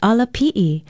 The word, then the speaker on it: ala pi'i: Aloha, from Hawaii Public Radio, and our Hawaiian word for today is ala pi'i. Ala pi'i is spelled A-L-A-P-I okina I, and it means stairs, steps, ladder, or any ascent.